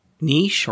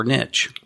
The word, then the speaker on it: niche: I'm going to wipe out somebody's example here, but is it niche or niche? – Well, niche. – That's one of those words. – Yeah. Niche is certainly the French pronunciation.